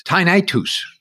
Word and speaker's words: tinnitus: He has feathers and things around, but he assured me that the correct way to say that word is tinnitus. So I don't know whether people want to start saying it like that. But that's the way Gus said tinnitus or tinnitus.